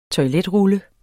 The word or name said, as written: toiletrulle